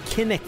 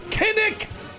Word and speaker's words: Kinnick: Kinetic. How do you say it? Kinnick. Kinnick! 14 to 13.